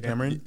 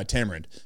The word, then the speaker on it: tamarind: Indian, tamarind. Is that how you tamarind? – Pronounce it? Yeah, tamarind.